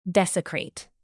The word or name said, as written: desecrate